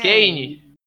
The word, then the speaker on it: Kane: Ah, Ken, Ken, Ken. Harry Ken. Quem é? Quem é? Kane.